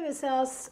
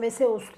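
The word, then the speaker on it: Vesaas: Veseyas, e, aslında Veseus diye okunuyor. Veseyas yazıyor. Veseus.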